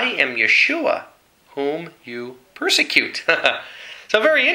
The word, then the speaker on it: Yeshua: There's no letter J in Hebrew. So if he's speaking in the Hebrew dialect, clearly he's going to say his name, which is Yeshua, Yahweh's salvation.